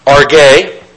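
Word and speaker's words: orge: Again, this can be pronounced arge.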